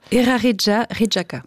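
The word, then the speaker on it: Eraritjaritjaka: Erari Jarijaka. Quelque chose comme ça? Vous voulez me le dire à ma place? – Erari Jarijaka. – C'est mieux. Musée des phrases de Heiner Goebbels.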